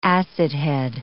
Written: acidhead

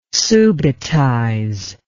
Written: subitize

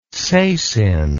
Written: sasin

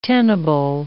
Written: tenable

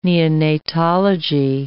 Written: neonatology

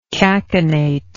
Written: cachinnatory

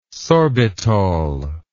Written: sorbitol

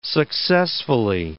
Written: successfully

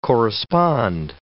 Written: correspond

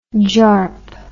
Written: jarp

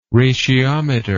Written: ratiometer

